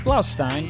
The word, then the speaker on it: Blaustein: Did I pronounce that right? Blaustein? – No, you didn't, but I don't care. – How do I pronounce it? – It's Blaustein.